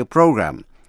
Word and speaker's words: program: Language Training Program, chương trình huấn luyện ngôn ngữ, chữ program, P-R-O-G-R-A-M, có hai cách đọc, có thể đọc là program hay là program. Language Training Program, chương trình huấn luyện ngôn ngữ, available, A-V-A-I-L-A-B-L-E, available, sẵn sàng.